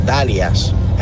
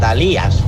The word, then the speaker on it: Dalías: No es Dalias, es Dalías, con acento en la I, no en la A.